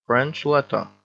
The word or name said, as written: French letter